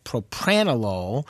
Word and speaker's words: propranolol: Beta blocker, I would try propranolol, P-R-O-P-R-A-N-O-L-O-L.